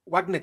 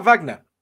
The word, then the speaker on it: Wagner: It's not Wagner. It's Wagner. It's the musician is Wagner because it's German. This is a Russian group, so it's Wagner. You can't use a German pronunciation for a Russian organization, even though they're named after them.